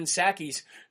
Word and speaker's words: Psaki: by telling everybody what Jen Psaki's new nickname would be. He said, since the P is silent in Psaki, let's just call her Psycho Psaki.